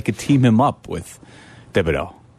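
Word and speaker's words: Thibodeaux: Is it pronounced Thibodeau? – Kayvon Thibodeau. Yeah.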